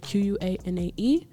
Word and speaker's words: Qua'Nae: My name is spelled Q-U-A-N-A-E. Let's talk with Qua’Nae, no apostrophe.